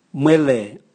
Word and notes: mwele: Raga

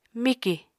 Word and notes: mikì: Apatani

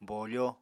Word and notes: bofo: Apatani

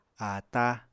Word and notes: ata: Apatani

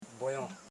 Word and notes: rojon: ro.jon

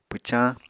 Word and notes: pwcañ: Apatani

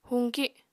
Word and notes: huñki: apatani

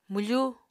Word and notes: mwfu: Apatani

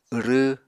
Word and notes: wrw: Apatani

